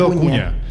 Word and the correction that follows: Acuña: A Manuel Acuña. Ah, te Manuel Ocuña.